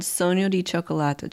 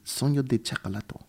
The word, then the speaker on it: Sogno di Cioccolato: It's called Sogno di Cioccolato, Chocolate Dream. – Chocolate. Sogno di Cioccolato. – Mm-hmm. – Did I say that right? – Yeah, you did a good job.